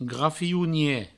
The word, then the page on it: grafionier: grafiounie; Provençal